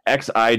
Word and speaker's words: Xi: XI Jinping. XI sounds more like she than G. The X sound is actually kind of difficult to explain.